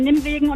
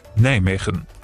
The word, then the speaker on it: Nijmegen: Ja, nee, ich bin tatsächlich heute auf der Autobahn am Schild vorbeigefahren, Nimmwegen und Neinwegen.